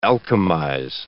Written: alchemize

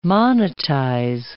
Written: monetize

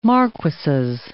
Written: marquises

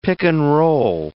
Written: pick-and-roll

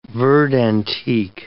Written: verd antique

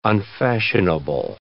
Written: unfashionable

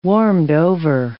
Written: warmed-over

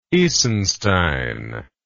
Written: Isenstein

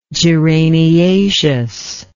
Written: geraniaceous

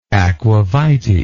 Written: aquavitae

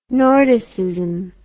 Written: Nordicism

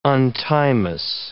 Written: untimeous

